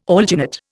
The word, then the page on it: alginate: Indian